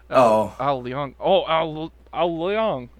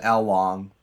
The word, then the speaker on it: Leong: Oh, did I not pronounce it right? – No, I was just kind of like, I had like a delay. I was like, Al Leong. Yeah. – Yeah. I think I pronounced it Al Long, which is probably wrong, but whatever.